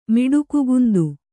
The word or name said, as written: ಮಿಡುಕುಗುಂದು